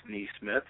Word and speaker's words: Nesmith: It is pronounced Nesmith, so very good job by you.